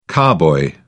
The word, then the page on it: carboy: British English